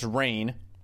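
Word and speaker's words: Rahne: So the coach of Old Dominion, Ricky, I believe it's pronounced Rain, they've adopted a young yellow lab puppy for the next 18 months.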